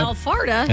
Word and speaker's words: Alpharetta: Alpharetta. – Alpharetta. – Alpharetta. That's Alpharetta, you. Georgia. That's where you live. – Alpharetta, Georgia. I missed a syllable there.